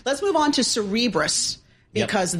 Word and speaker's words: Cerebras: How do you say your name? Like Cerebral. – Yes. – So Cerebras shares soaring.